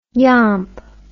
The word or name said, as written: yomp